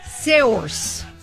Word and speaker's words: Saoirse: S-A-O-I-R-S-E. S-A-O-I-R-S-E. – Source. Nailed it, right? Nailed it. No? – Okay, so I tell you what, this will be your hint. I'm going to get Saoirse Ronan to pronounce them.